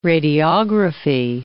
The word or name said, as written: radiography